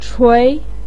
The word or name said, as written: chui2